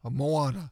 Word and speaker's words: Moana: Moana? – Moana.